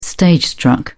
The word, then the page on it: stage-struck: British English